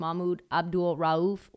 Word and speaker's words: Mahmoud Abdul-Rauf: Mahmoud Abdul-Rauf, once.